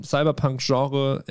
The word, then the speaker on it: Cyberpunk: die eine ähnliche Liebe zum Cyberpunk-Genre empfinden. Betonung liegt hier, habe ich im Podcast auch damals immer schon gesagt, Betonung liegt hier auf Punk, ja, nicht so auf Cyber,